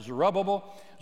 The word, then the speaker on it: Zerubbabel: And under the leadership of a man by the name of Zerubbabel, or you can pronounce it Zerubbabel, Zerubbabel and Ezra and Nehemiah, they go back to the land of Canaan.